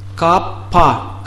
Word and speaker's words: капха: Итак, капха. Капа. Хэ не читается, поэтому как капа.